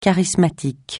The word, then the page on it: charismatique: francais